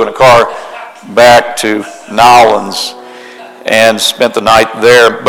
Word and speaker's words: New Orleans: We did have an opportunity we flew into New Orleans, and let me say it right, it's Nolens. It's Nolens. It's not New Orleans, it's Nolens.